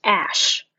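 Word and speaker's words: Æ: Anyway, so the way to pronounce it is X, as in ex-boyfriend. So it's X, and the A with the E on top of it is pronounced Ash.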